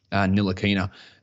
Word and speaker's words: Ntilikina: They were talking about Ntilikina. It's a silent T, which I always used to go to silent N, but it's a silent T.